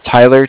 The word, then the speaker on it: Tyler: Okay, my name is Tyler, T-Y-L-E-R. And I'm calling with Cardiff. It's spelled C-A-R-D-I-F-F, as in Frank.